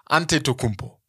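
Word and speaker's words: Antetokounmpo: Aus dem D bei AD Tokumpo wurde ein NT, ein Ante, Ante, Tokumpo. Weil im Griechischen gibt es kein D. Mm. So. Aus dem O wurde aufgrund der Aussprache, aus dem AD Do Kumbo, wurde ein Ante To ein OU. Ja. Das war aufgrund der Aussprache im Griechischen. Das ist, hören wir jetzt gerade selber gar nicht raus. Bei uns mhm. ist es immer noch ein O, aber bei dem war es dann ein OU.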